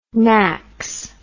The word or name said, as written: NACS